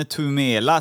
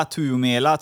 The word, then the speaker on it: Tuomela: För idag så ska vi få träffa eller inte träffa, men vi ska få lära känna Janne Tumela, Tuomela tror jag det eh, uttalas.